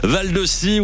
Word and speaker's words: Val de Scie: Mais Val de Si, ça s'écrit Si, S-I, ou Si comme une S-C-I-E. Comme une scie. Comme une scie. Absolument, y avoir des bûcherons par là. Val de Scie, oui, un point.